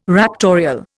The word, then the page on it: raptorial: Indian